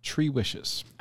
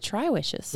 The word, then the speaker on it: Tri Wishes: And for a long time, we called it Tri Wishes. – Because it's spelled Tri. – T-R-I. And then we learned from a member of the Runcation Nation, Tyler, how we should pronounce it.